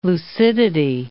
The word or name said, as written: lucidity